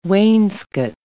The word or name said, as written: wainscot